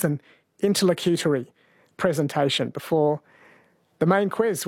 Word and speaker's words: interlocutory: It's pronounced interlocutory. Sorry, interlocutory. That is correct, yes.